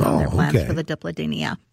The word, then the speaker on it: Dipladenia: It is spelled D-I-P. L-A-D-E-N-I-A. – D-E-D-I-P? – Diplodenia.